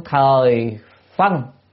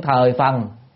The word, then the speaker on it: Thời Phần: Thử Phiên Thời Phân, hay là Thời Phần, ở đây nên đọc là Phần. Thử Phiên Thời Phần,